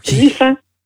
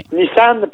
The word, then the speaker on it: Nissan: Mais on devrait, et, ça, c'est notre ami Didier qui va être content, selon la prononciation originale, on devrait prononcer Nissan. – Nissan, OK. – Nissan, parce que ça vient de sang OK. – Donc, on devrait prononcer Nissan, ce que les Français font et que nous, on ne fait pas.